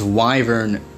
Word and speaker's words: wyvern: It's wyvern, you morons. Pronounce it correctly. It is wyvern, not wyvern.